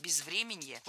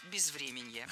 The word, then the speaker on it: безвременье: Безвременье, неверно, правильно, безвременье. Ударение в этом слове падает на корень врем. Время, безвременье.